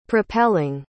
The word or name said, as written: propelling